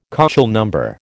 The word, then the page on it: KÔchel number: American English